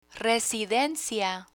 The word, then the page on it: residencia: spanish